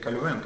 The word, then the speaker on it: Кальвин: Мартин Лютер, ну не он один, вы правда, Кальвин, Свингли, да, вообще правильно говорить Кальвен, как оказалось. Но мы привыкли Кальвин, да?